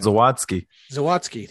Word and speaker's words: Zawadzki: So getting that back and then having, I'm going to totally butcher his name. Say it again. – Zawadzki. – Thank you. Having Zawadzki come in, and you're like, who, like, I kept hearing the name.